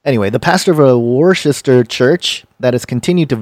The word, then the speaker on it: Worcester: Worcester. – It's not Chester because it's not CH. Worcester? – No, I know, but it's a long-standing debate.